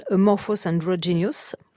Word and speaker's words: Amorphous Androgynous: Amorphous Androgynous.